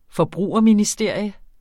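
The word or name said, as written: forbrugerministerie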